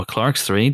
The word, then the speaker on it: Clerks: Yes, but if all goes well, there's a film out next week called Clerks 3. Or Clark's 3, depending on your pronunciation.